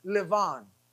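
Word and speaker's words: Lavan: But in Hebrew, Laban's name, as I said, is pronounced Levan. Levan. So the word for heart is Lev in Hebrew. And so Jacob stole the Lev of Levan.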